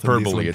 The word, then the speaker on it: hyperbole: Well, hyperball. – I don't know. My pronunciation might be very off. – Hyperbole. It's okay.